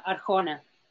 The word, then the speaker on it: Arjona: It's A-E-R-I-A. Huh? Arjona? – The last name is Arjona. – Thank you. It's A-R-J-O-N-A.